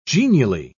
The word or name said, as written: genially